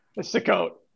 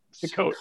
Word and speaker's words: Sukkot: So one time we were on the train, and maybe I pronounce it wrong. Maybe it's insulting, but I thought it was suck it. – It is definitely not. – It's not the holiday of suck it. – No, and it's not Yom Kippur, as Mike would say. – I told Andy that one. – This is insulting. We should no longer say Sukkah. – The holiday. – Are you talking about Sukkah? – Sukkot. Sukkot.